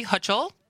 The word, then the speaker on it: Hochul: So last week, New York Governor Kathy Hochul, did I pronounce that right? – I think so.